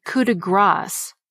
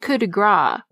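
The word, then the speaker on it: coup de grâce: And the finishing touch on a job is the coup de grace, because grace is spelled G-R-A-C-E. It's not coup de gras, which literally means a strike of fat.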